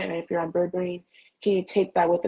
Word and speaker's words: berberine: It's called berberine. It's spelled B-E-R-B-E-R-I-N-E.